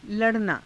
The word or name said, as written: la_ranaa